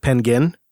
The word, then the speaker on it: Pengwin: And I'm getting this wrong because it's based on a Japanese pronunciation that is probably pronounced Pengin. But so I think that's how you pronounce it, is Pengin. It's going to be P-E-N. G-W-I-N.